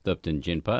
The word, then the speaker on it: Thupten Jinpa: His name is Thupten Jinpa.